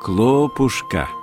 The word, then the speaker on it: Клопушка: Между тем, речь в этой главе пойдет вовсе не о хлопушках, а об одном маленьком существе по имени Клопушка с ударением на первый слог.